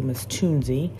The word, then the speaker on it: Tunzi: And the winner is Miss Tunzi. I'm saying her name correctly, Miss Tunzi.